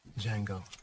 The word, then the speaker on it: Django: Django. D-J-A-N-G-O. The D is silent.